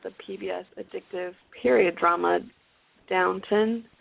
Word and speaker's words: Downton: I thought it was Downtown Abbey, but. – Me too. And I'm like, oh. It's Downton.